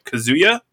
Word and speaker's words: Kazuya: Kazuya? Is that how you say his name? – I believe so.